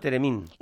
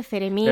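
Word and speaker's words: theremín: ¿Es ceremín o teremín? – Bueno, es lo mismo. – Se escribe teremín, con TH, ceremín.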